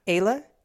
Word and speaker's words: Ehle: I don't know how to pronounce that last name. E-H-L-E. It's a weird last name. – E-H-L-E. Ayla? – Sure.